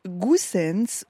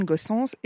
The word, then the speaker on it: Goossens: Soit je vous la fais à la flamande, soit Eugene Goossens, ou alors je vous dis Eugène Goossens, et dans ce cas-là, vous savez de qui on parle.